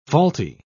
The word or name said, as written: faulty